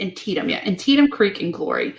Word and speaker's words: Antietam: clearly, though, today's depictions of battlefield scenes, the attack on the sunken road and how do you say that word again? – Antietam. – Antietam, that's right.